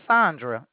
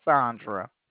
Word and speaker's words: Sandra: My name is Sandra. – Sandra. Okay, and Sandra. – oh, Sandra. – No, it's Sandra. It's spelled Sandra. It's pronounced Sandra.